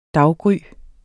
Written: daggry

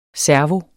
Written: servo

